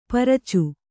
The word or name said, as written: ಪರಚು